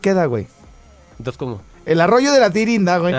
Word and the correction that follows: Pirinda: Eh, que aparte no es de Tirinda, güey. es Pirinda. Te equivocabas. – sí, güey, siempre lo dije mal.